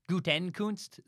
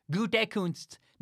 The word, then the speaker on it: Gutekunst: I know it's Gutekunst, not Gutenkunst.